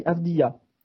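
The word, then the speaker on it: Avdija: Alex, quelle proposition Denis, alors attention Angelo, s'il vient dans ta franchise, on dit Denis Avdia. visiblement, c'est on s'est fait reprendre par c'est de, par les origines de son père, euh, euh, on dit Avdia, voilà, c'est juste une, question de prononciation.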